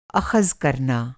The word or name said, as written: अख़्ज